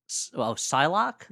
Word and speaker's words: Psylocke: You're Cyclock. – Cyclock. So the guy was a clock instead of an eye. – How do you pronounce that? – Psylocke.